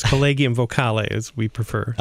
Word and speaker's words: Collegium Vocale: It was very close. Collegium Vocale, as we prefer. – Aha. You want to make sure we pronounce all of the vowels there.